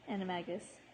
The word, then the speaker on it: Animagus: Animagus. – I think it's a hard G, Animagus.